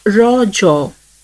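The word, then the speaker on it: Raja: The festival is natively pronounced as rojo, a local term that means menstruating woman.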